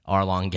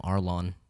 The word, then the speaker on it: Arlong: I keep calling him Arlong. – Yeah. Just drop the G. The G is silent.